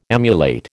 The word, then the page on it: emulate: American English